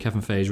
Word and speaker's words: Feige: Well, the rumour is that Kevin Feige rhymes with beige, thinks this is going to be too similar to Mr. Fantastic in Fantastic Four, so he wants to tweak her powers.